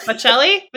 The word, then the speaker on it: Bocelli: Not Andre Botticelli. No, is that the chef? It's Bocelli? Bocelli? – Okay, slow down.